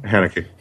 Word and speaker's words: Haneke: How do you pronounce his last name? – Haneke. – Haneke.